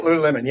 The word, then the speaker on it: Lululemon: Lululemon, yeah.